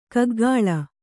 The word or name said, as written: ಕೂಕಿಲು